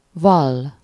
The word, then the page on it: confess: /kən.ˈfɛs/; [kən.ˈfɛs]